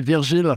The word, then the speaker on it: Virgile: Yeah, it's actually Virgile, Raingeard, but it's a pretty tough name for English-speaking person to pronounce, so no blame there, really.